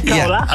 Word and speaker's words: Khao Lak: Is it Khao Lak? Am I saying that right? – Yeah, Khao Lak.